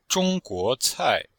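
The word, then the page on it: zhongguocai: zhong1guo2cai4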